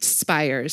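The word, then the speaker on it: Spiers: this is Elizabeth Spears that I'm quoting here. Excuse me. It's pronounced Spires. – Spires. – I've actually said that in her Twitter bio. – S-P-E-I-E-R-S, right? – She's a writer.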